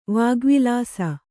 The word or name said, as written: ವಾಗ್ವಿಲಾಸ